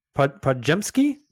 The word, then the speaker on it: Podziemski: Podziemski. I'm probably mispronouncing that. – No.